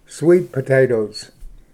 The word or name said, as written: sweet potato